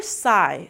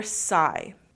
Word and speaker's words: sigh: Agora, se lembra aquele IGH que eu falei pra você? Aquele long I. Então, mesmo que você veja do G, o G, né? E o H, não precisa falar sig, h. A gente simplesmente vai fazer I. Your sigh.